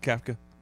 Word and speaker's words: Kafka: The interim guy, Kafka. Is that how you say his name? – Yeah. – Mike Kafka.